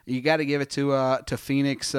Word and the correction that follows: Penix: Uh, the I am pronouncing that right, Phoenix. Penix? Penix. Penix. I knew I was pronouncing it wrong.